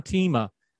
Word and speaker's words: Teema: We're going to talk about it with our friend, Sean Teemian. Did I get it right? I was practicing. – Teema. – See, I'm a hillbilly. We can't do those vowel sounds.